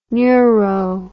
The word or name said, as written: neuro